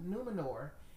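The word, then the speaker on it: Númenor: Now, I did read that Numenor, am I saying that right? – Numenor, it was in the book.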